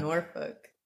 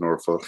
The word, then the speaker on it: Norfolk: It is Norfolk. – It's spelled like folk, though. – They don't ever put the L and the K on them.